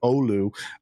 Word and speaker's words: Oulu: There's a Prodigy's Pro Tour event, and I swear this is a Hawaiian city, so I don't know how to pronounce it, but Olu, OULU. That's Hawaiian. It really is, but apparently it's Finnish.